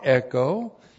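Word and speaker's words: Echo (ἔχω): E-C-H long O. Echo.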